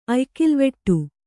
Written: ಅಯ್ಕಿಲ್ವೆಟ್ಟ

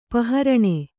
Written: ಪಹರಣೆ

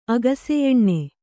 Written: ಆಕಾಶಯಾನ

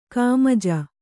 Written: ಖಮಾಜ

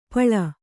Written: ಪಿಳ್ಳೆ

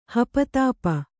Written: ಹಪತಾಪ

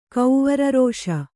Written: ಕೌವರರೋಷ

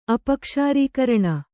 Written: ಅಪಕ್ಷಾರೀಕರಣ

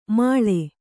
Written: ಮಾಳೆ

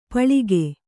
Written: ಪಳಿಗೆ

